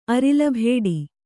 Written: ಅರಿಲಭೇಡಿ

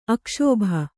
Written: ಅಕ್ಷೋಭ

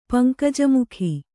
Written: ಪಂಕಜಮುಖಿ

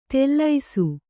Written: ತೆಲ್ಲೈಸು

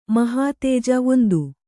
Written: ಮಹಾತೇಜ

